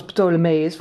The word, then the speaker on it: Ptolemeus: Je moet zijn naam uitspreken als Ptolemeus, want anders wordt hij blijkbaar kwaad.